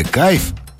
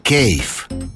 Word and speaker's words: кейф: В слове «кайф» английского языка нет даже близко. И вообще правильно говорить «кейф».